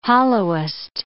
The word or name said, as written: hollowest